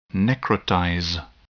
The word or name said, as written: necrotize